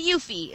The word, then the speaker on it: Yuffie: I'm the great ninja Yuffie. It's Yuffie. – Yuffie. Okay.